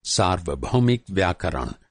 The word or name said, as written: सार्वभौमिक